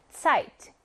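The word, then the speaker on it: Zeit: 알파벳 Z는 마치 S가 들어가 있는 것처럼 여러분 자꾸 생각하는데 완전히 다른 발음이에요. 무슨 발음? 츠 발음입니다. 차이트.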